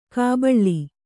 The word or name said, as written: ಕಾಬಳ್ಳಿ